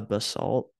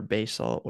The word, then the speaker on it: basalt: And the reason for that is most of the black sand is actually a basalt or basalt, whatever you'd like to Yeah, basalt. – pronounce it as.